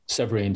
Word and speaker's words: Severin: Severin, am I pronouncing your name correctly? – Yeah, it's Severin. – Severin, sorry.